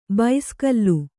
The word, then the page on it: ಬೈಸ್ಕಲ್ಲು: baiskallu